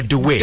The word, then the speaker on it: Doué: Is it Doué or Doué? – Doué. – Doué. – Doué, yes, yes.